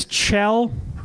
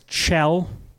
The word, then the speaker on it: Kjell: My name is Chell. It's Chell. Spelled K-J-E-L-L. Pronounced Chell.